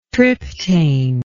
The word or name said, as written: triptane